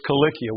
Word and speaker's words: Cilicia: It's Cilicia with a hard K, a sound. The C is hard, and if you look it up in the Greek, it is spelled with a kappa, with a K.